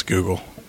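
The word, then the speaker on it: Googol: It's spelled kind of funny. It's G-O-O-G-O-L, I think, if I'm not crazy, but it's Google.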